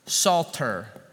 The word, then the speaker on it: Psalter: The word is psalter, not like a salt shaker sort of psalter. Okay, you can see the silent P on the front of it.